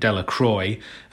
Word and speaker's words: Delacroix: Bobby and Harry Jones and Dicky Delacroix, the villagers pronounced his name Dellacroy,